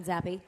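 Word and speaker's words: Zappe: Is it Zappi or Zap? I've heard it pronounced Zappi. – Before. Zappi.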